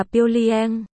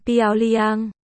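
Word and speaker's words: Piêu Liang (漂亮): Xinh, trong tiếng Trung là Piêu Liang, phiên âm Piêu Liang.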